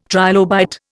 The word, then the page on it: trilobite: Indian